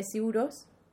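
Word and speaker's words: Sigur Rós: Una es de Siuros, que es un grupo islandés muy chulo, se escribe Sigur Ross, ¿vale?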